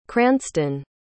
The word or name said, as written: cranston